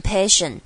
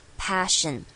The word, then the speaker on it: passion: 好，finding your passion thing，这个地方 passion 容易会忽略掉，可能念成 passion，但是它这边是一个 apple 的 a passion， passion thing is that。